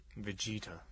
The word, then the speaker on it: Vegeta: How do you say it? – Vegeta. – Vegeta.